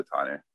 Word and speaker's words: Tane: It's like if we ever have a special night that we want to go out, we go to Tane. They've got the best. It's like the it's amazing. – Wait, spell that for me. – T-A-N-E. – Oh, Tane. – Tane Vegan Izakaya.